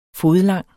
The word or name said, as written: fodlang